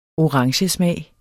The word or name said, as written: orangesmag